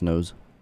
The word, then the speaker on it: Knouse: Before that, we heard an artist by the name of Max Nose, K-N-O-U-S-E, but I think it's pronounced Nose,